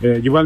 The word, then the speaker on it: Juval: Y pueden, para que les sea más fácil encontrarlo, es Yuval Music, se escribe JUVAL y Music como música, pero sin A.